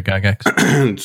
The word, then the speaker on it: Gygax: No, that was like when, you know, D&D was picking up with Gary Gygax. Am I saying that correctly? – Yep. Right. Yep. Gary Gygax.